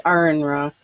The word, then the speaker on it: Aaron Ra: But one of the things that keeps coming up, apparently, and this happened even with Aaron Ra, as I recall, and I hope I pronounced it correctly. – It's Aaron Ra.